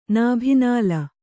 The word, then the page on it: ನಾಭಿನಾಲ: nābhi nāla